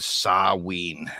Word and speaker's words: Samhain: It's not out of any kind of like disrespect or anything, but like I came across the name Samhain from Danzig's band in the 80s, you know, like, and I've always heard everyone pronounce it that way. And apparently the technical way to pronounce that is Saween.